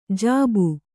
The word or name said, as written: ಜೀಬಿ